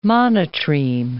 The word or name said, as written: monotreme